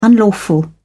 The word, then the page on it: unlawful: British English